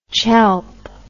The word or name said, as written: chelp